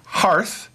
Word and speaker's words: hearth: Most recently, mispronunciation, unpronunciation, wrong pronunciation, poor pronunciation of the word hearth. And as he kept saying heath, I even asked him, what the hell's a heath? – I didn't say heath, I said hearth.